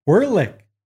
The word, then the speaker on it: Werlich: Werlich. – So you all know that's how you say it.